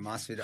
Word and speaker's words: Masvidal: Brother, your boy, I can't even pronounce his name Masvidal? – Right. Masvidal.